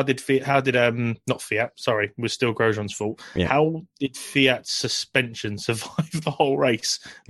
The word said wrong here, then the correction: Kvyat: I've been assured that it's Kvyat. Kvyat.